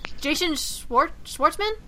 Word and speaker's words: Jason Schwartzman: Jason Schwartzman, if I'm pronouncing that right.